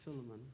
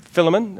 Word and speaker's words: Philemon: When I think of this and I put myself in the place of Philemon, that's how I pronounce it. – Yep, Philemon, that's great.